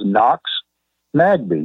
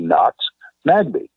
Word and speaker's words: Noxmagbe: And the word was Knox Magbe. You know, if you were to spell it phonetically, N-O-X-M-A-G-B-E, Knox Magbe.